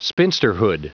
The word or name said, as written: spinsterhood